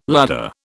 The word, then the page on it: ladder: British English